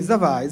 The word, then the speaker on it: Zavai: Si chiama Zavai. – Sì. – Ho detto bene? – Sì, Zavai.